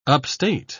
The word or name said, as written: upstate